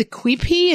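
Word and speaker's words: quipu: And you talked about, I believe it's the Quipi, is that how it's pronounced? – Oh, yeah.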